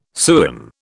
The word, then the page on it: sewin: British English